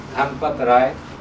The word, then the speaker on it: Dhanpat Rai: My name is Dhanpat Rai. – And how do you spell that? – D-H-A-N-P-A-T. – D-H? – D-H-A-N-P-A-T-R-A-I.